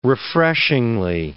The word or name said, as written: refreshingly